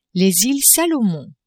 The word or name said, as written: Iles Salomon